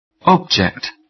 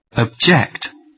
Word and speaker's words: object: Put the stress on the first syllable so that you'd pronounce the words as nouns. – 1. Export. – 2. Import. – 3. Increase. – 4. Object.